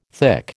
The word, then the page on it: thick: American English